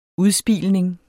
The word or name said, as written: udspilning